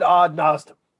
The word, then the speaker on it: Odd Nosdam: Is it Odd Nosdam? Odd Nosdom? Nosdom sounds better, but I don't know. – We all said Odd Nosdom.